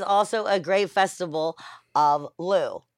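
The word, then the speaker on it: Lugh: Lou. – Lou. Okay. – Yeah. The G is silent.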